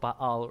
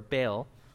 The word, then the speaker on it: Baal: they're called Baal or Baal, because we like to mess up the way that's pronounced, Baal, okay.